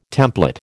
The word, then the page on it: templet: American English